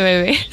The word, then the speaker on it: Bebe: ¿Bebé eh, o Bebe? – ¿Cómo se pronuncia? Yo creo que es Bebe, ¿no? Ah, pues sí, Bebé, vale. Sí, perdón, perdón. Pido perdón a los bebés. Es la cantante, la cantante Bebe.